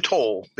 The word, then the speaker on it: Tau: It's actually Toll. – Toll, okay. – Ancestors were stubborn, and they didn't change the spelling to make it easy on people.